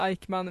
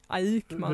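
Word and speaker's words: Eyckman: Det uttalas nog inte Aikman, men Aikman. – Hur stavas det? – det... E-Y-C-K-M-A.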